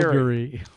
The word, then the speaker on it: Calgary: Is it Calgary or Calgary? – Calgary. It's like Campbell River or Campbell River. – It's Campbell River, but it's spelled Campbell River. It's like bagel or bagel.